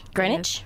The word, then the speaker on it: Greenwich: Did I say it right? It's Greenwich. Greenwich? Yes. Greenwich. – Yeah. The W silent, but that's okay.